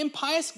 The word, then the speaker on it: impious: He says, when the impious, actually is the proper pronunciation, when the impious Galileans support not only their own poor, but ours as well.